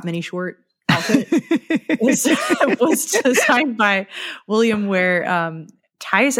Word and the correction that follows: Theiss: I think that's how you say his name. It's Theiss, famous Star Trek costume designer. We've talked about before, but I always pronounce his name wrong.